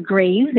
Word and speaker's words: Greaves: My name is Keisha, K-E-I-S-H-A. Last name is Graves, and that is spelled G-R-E-A-V-E-S. You can easily find me on Facebook and then on social media, Keisha Graves as well.